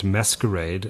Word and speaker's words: MSQRD: MSQRD, and it's pronounced Masquerade.